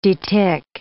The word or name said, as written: detick